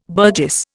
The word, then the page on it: burgess: Indian